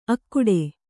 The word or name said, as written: ಅಖೋಟ